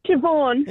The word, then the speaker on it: Siobhan: It's S-I-O-B-H-A-N. – Oh, isn't it pronounced Siobhan? – Siobhan. – Yeah, it's just Siobhan. – Why would you go out there and spell it S-I-O-B-H-A-N? – Yeah.